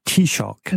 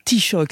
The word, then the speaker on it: Taoiseach: Alors, le terme gaélique, c'est Taoiseach. – Voilà, Taoiseach, c'est comme ce qu'on vient d'entendre.